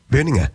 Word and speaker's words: Berninger: And I did look up how to pronounce Matt Berninger because there's always been for some time a debate whether it's Matt Berninger or Berninger. And he said that he answers to all but prefers the soft G, which I think makes it Matt Berninger.